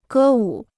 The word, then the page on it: 歌舞: gē wǔ